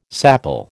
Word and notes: sapele: American English

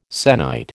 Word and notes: sennight: American English

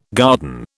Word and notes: garden: British English

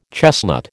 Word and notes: chestnut: American English

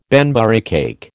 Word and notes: Banbury cake: American English

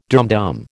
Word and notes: dumdum: American English

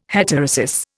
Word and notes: heterosis: Indian

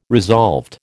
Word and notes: resolved: American English

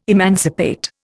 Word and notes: emancipate: Indian

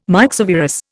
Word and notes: myxovirus: Indian

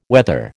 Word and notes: wether: American English

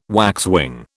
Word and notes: waxwing: British English

